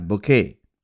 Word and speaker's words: Bucket: who insisted that her surname be pronounced as Bouquet.